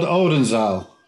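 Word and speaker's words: Oldenzaal: Was that called Odenzaal? – Correct. You've done your homework. Well done. – It's all about the pronunciation too.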